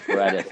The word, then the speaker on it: Paradis: It's pronounced Paradi, but it's spelt like Paradis. – Right, Paradis. So I always say paradise without the E.